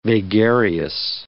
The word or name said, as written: vagarious